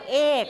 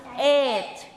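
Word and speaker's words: eight: เราจะออกเสียงว่าเอกซึ่งเหมือนกับคำว่าไข่ซึ่งไม่ใช่ต้องออกเสียงว่าเอทเอทไม่ใช่เสียงกอไก่นะคะเอทเอทนะเอทไม่ใช่เอก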